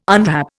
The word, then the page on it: unwrap: Indian